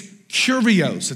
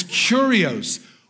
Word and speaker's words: Kyrios: When referring to Jesus, it's Kyrios. It doesn't look like that. That's how closest pronunciation you're going to get from me. It's Kyrios.